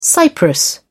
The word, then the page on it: cypress: English; British English